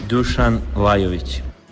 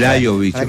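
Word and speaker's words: Dušan Lajović: A ver, Rodrigo de la Rosa, la pronunciación de Lajović. ¿Cómo es? A ver, que él mismo lo diga. – Dusan Lajović. – Dusan Lajović. – Exactamente. – Lajović. Aparece como una I, la J como I.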